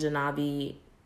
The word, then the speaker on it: Genevieve: Also, I've still been listening to the Rise EP by Willow and Genevieve.